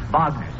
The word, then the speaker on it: Wagner: That's pronounced Wagner, son.